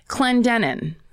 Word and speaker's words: Clendenin: Clendenin. – That's right. – C-L-E-N-D-E-N-I-N.